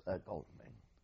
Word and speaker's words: Godalming: Yes, Godalming.